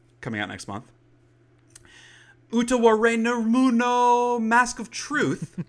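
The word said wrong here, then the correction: Utawarerumono: It is Utaware Rumono.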